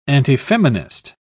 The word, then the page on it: antifeminist: British English